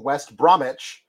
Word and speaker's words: West Bromwich: My team, West Bromwich, the W is silent, West Science Albion, are sponsored by a heating company.